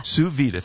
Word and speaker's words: sous vide: Sous Vide, thank you. Sous Vide Supreme Water Oven.